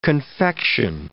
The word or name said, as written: confection